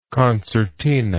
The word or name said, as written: concertinist